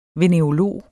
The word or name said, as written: venerolog